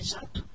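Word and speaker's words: jacto: A palavra jato é escrita com o C antes do T em Portugal, mas no Brasil se lê sem C. Jato.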